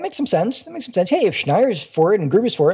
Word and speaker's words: Schneier: So when I first read Gruber's little article where he mentioned Schneier's article, and I'm pronouncing that right too, right? – You are correct.